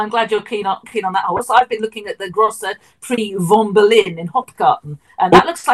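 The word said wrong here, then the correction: Großer Preis von Berlin: Surely the Grosse Preis von Berlin. – Sorry, the correct pronunciation.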